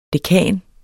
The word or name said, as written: dekan